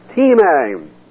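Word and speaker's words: time (τιμή): Honor looks like the word time. We've had it before. It's pronounced time.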